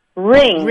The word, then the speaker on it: wring: Ring. – Ring. – So, it's not the ring on your finger, no, it's W-R-I-N-G.